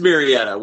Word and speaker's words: Murrieta: It is Murrieta. We're church right now establishing a place in Wildomar, which is right next to Murrieta,